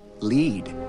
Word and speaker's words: lead: It says lead. Like lead balloon. – I believe it also is pronounced lead, as in leader, leadership. Lead the way.